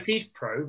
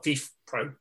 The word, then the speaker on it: FIFPro: The FIFA Pro, which is spelled F I F P R O, is the. Thanks for spelling that. Well, FIFA Pro.